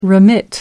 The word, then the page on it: remit: American English